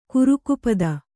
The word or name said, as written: ಕಾರಕಪದ